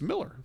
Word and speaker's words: Millar: And it's spelled Millar, but it's Miller.